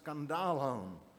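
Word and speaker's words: skandalon (σκάνδαλον): In the original language, it's pronounced skandalon.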